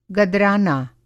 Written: गदराना

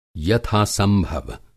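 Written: यथा संभव

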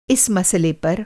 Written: मसला

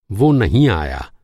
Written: नहीं

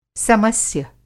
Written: समस्य

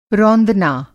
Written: रौंदना

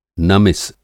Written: नमिस

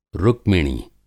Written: रुक्मिणी

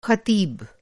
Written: ख़तीबः

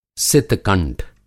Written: सित-कंठ

